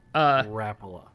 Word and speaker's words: Rapala: Rapala sponsored dudes, but oh come on, say it right. – Rapala. – Rapala. – No, Rapala.